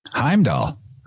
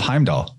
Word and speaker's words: Heimdall: And what's the first syllable of Heimdall? Ham.